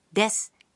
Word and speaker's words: です: The vowel u in su of desu is silent, so pronounce it like des.